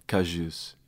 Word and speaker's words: Cajuste: And then Cajuste, the number 86. No underscore hyphen or anything. – Cool. That's a silent T for anyone keeping track. – Cajuste. – Silent T-E. – Mm-hmm. Right. – Cajuste.